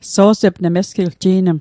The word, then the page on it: ji'nm: Mi'gmaq